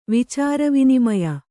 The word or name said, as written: ವಿಚಾರವಿನಿಮಯ